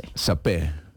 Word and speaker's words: saper: Yo creo que la R al final no se pronuncia, pero pues no sé. – Sapé.